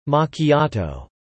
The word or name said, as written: machiatto